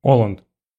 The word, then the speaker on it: Оланд: Оланд. По-моему, Оланд. – Но он француз, ударение на последнем.